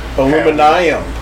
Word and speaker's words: aluminium: Aluminium? – Yeah, sure. Whatever you're trying to say there. – The UK pronunciation of aluminum. – Aluminium.